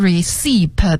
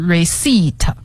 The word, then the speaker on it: receipt: 这个 receipt 的拼写给大家再拼一下，是 receipt，它最后呢是有一个 p 的，但是呢我们不读出来，对，这个 p 是不发音的，所以不念成 receipt 而是 receipt。